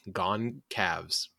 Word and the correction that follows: Gonsalves: So I actually got pronunciation on it. It's Gonsalves.